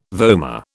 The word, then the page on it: vomer: British English